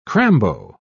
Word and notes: crambo: British English

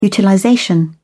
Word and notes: utilization: British English

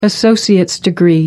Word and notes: associate degree: British English

